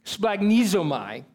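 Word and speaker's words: splagchnizomai: But compassion in the Greek, it's got this really complex pronunciation for this Greek word. And it carries it. It's splagnizomai, okay? That is the word.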